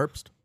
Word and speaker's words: Herbst: How do you pronounce Herbst? – It? Herbst.